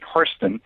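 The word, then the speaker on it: Hairston: I wrote a long study of an African-American family named the Hairstons, and there's a white family that shares the same name, and they pronounce it Hurston,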